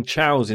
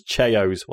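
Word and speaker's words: Chao: Spy Duck says, question, I liked raising chows in Sonic Adventure 2. What is your favourite game / activity within a game? I pronounce them as cheos. Well, that's incorrect, Spy Duck. It's pronounced chow, but never mind.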